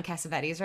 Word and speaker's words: Cassavetes: Am I saying John Cassavetes right? – Yeah.